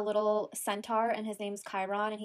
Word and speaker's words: Chiron: Chiron? – Chiron.